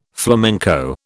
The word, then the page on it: flamenco: British English